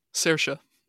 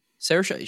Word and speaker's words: Saoirse: I don't know how to pronounce her name. Saoirse. Saoirse Ronan. Saoirse, she is so talented.